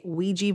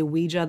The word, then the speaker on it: Ouija: And I want to say real quick that here in America, we tend to say Ouija board, when I know in other parts of the world, it's Ouija. I think that's the actual correct pronunciation,